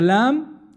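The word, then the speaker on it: Lam (لام): It's coming from your tongue, from the middle of your mouth. Right? It's not coming from the back of the throat. Alif. It's coming from the back. Lam.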